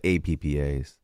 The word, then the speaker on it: Appa: But APPAs.